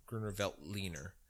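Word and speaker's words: Grüner Veltliner: So he had like a Gruner Veltner. Is that, am I pronouncing that correctly? It's a white German varietal. – You might be, but I might not be thinking of the right one. – It might be Gruner Veltliner. That might be the correct pronunciation.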